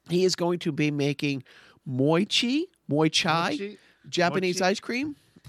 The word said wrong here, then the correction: mochi: Mochi is the Japanese rice cake,